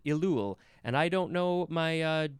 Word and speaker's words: Elul: The next word is elul.